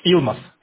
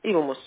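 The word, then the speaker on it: Yılmaz: Фирма Улмас, или как она правильно читается? Илмас? – Илмас. – Илмас. – Илмас. – Вот. Буду знать.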